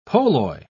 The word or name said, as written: polloi